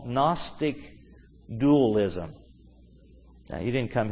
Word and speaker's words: Gnostic: And even it's even a funny word because it starts with a G but pronounced with a N. Gnostic.